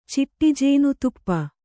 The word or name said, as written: ಚಿಟ್ಟಿಜೇನುತುಪ್ಪ